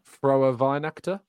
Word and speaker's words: Frohe Weihnachten: Is it Frohe Weihnachten? Is that how I pronounce it correctly? I can see it in the chat. – Pronounce that correctly? Yeah, come again, so that I can correct you if you're wrong. Can you come again, please? – Yeah, Frohe Weihnachten.